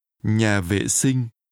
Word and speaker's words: nhà vệ sinh: Nhà vệ sinh. Sinh. Vệ. Vệ sinh. Nhà. Nhà vệ sinh. – Bathroom is pronounced with low falling. – Nhà. – Low falling broken. – Vệ. – And mid-level tones.